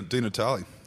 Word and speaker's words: Di Natale: Well, Senator Di Natale, thank you for joining us today.